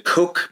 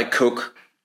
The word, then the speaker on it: Koch: the Koch brothers, the Koch Industries is a huge private sector. And by the way, Koch is spelled K-O-C-H.